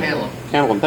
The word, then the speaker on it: tantalum: How is that pronounced? I've heard tantalum, tantalium, all sorts of... – Tantalum, thank you. All sorts of strange variations.